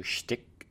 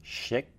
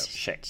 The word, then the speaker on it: Schick: Shall we dig into shtick? No. Schick. There we go. Schick. – Schick. I think we should. – The T is silent.